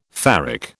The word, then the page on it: ferric: British English